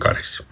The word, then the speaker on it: Karis: Karis, säger man Karis. – Karis. – Karis. – Karis. Finns det andra sätt? – Ja. – Karis.